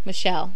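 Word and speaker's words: Michele: Leah Mitchell. – Michelle. – Sorry. Leah Michelle. I've been saying it wrong this whole time. I've always thought it was Mitchell.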